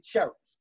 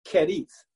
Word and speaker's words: Cherith: Now, most preachers say cherith. It's C-H-E-R-I-T-H. But in Hebrew, it's pronounced kerith.